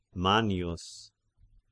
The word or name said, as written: Mannius